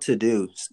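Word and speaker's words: Teuxdeux: It's called To-Do, but it's spelled in French, T-E-U-X-D-E-U-X. T-E elephant U as in umbrella, okay.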